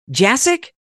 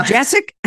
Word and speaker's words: Jasek: Dr. Jasek? Yep, yeah. I know her. – my name, yeah, it's like, it doesn't really look, it's really pronounced very much the way it's spelled, but it's just different.